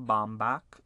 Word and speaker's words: Baumbach: Directed by Noah Baumbach.